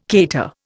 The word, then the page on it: cater: Indian